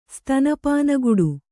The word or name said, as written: ಸ್ತನಪಾನಗುಡು